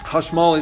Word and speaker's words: hashmal: Hashmal is the Hebrew pronunciation, if you were wondering.